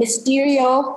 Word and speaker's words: Mysterio: So you saw them in Far From Home with, I'm forgetting, Mysterio, am I saying it right? Yes.